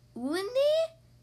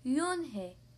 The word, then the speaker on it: Unhei: "Woonie?" – the girl asked, scrunching up her face. – "Ooh, ooh, Woonie!" some kids chanted. – No, Yoon-hae corrected. "It's spelled U-N-H-E-I. It's pronounced Yoon-hae."